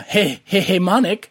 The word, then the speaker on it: hegemonic: Well, he is, which is coming from a feminist perspective, uses hegemonic – did I say that right, Evan? – It sounds right. – Yeah, hegemonic.